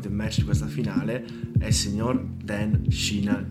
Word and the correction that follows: Sheehan: di Leicester. – Che Sheehan, ha fatto... – Comunque. Sheehan, vabbè, perché ho l'accento di, di Galway che a volte non si capisce, scusami.